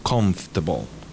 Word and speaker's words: comfortable: Ok, entonces confortable, vamos a marcar la M y vamos a eliminar la segunda O de confortable, con lo cual se diría comfortable.